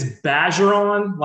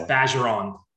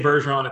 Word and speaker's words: Bergeron: So it's like, it's Bergeron, like badger. It's like saying badger, but it's Bergeron. You can say Bergeron, it's totally fine. But if you're in Louisiana, that's how you say it. It's French.